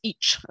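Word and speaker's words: ic: So uh, in spelling it was it was the letter I and the letter C, and in pronunciation it was ich. so so palatalized ch.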